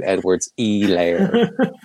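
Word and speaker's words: Edwards-Helaire: The one thing I don't love about the Chiefs is the way that Monday night announcers pronounce Clyde Edwards E-layer. That's the one thing. – Apparently that's it. I went back and watched an SEC video and that's how he pronounces it. So I guess I need to change my pronunciation. – So it's a silent H? – It is.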